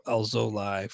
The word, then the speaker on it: Alzolay: Looks like his pronunciation is not on Baseball Reference, but I feel like I saw a tweet that said the end of his last name is pronounced Lie, so I'm going to go Alzolay for now.